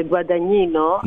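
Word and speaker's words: Guadagnino: Δηλαδή δεν είναι ακριβώς το ίδιο πράγμα, γιατί ο Λούκα Γκουαντανίνο, γιατί έτσι προφέρεται, Γκουαντανίνο, ναι, αλλά δεν μπορεί να προφερθεί στα ελληνικά, έχει κάνει πολλές ταινίες, έχει ξεκινήσει από πολύ παλιά, αλλά είχε κάποια ups and downs στην πορεία του.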